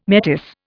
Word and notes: metis: Indian

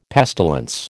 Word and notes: pestilence: American English

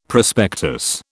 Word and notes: prospectus: British English

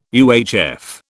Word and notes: UHF: British English

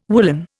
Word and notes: woollen: Indian

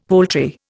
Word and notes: poultry: Indian

